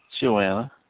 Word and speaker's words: Joanna: And it was Mary Magdalene, and Jonah, and, did I say Jonah? Joanna? I think that's not pronounced right. Joanna or Jonah? – Yes, Joanna.